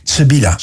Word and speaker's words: Sibilia: Sibilia, 31 oktober komt die uit. Sibilia, ja, zeg ik het goed? Sibilia, zo moet ik het zeggen inderdaad.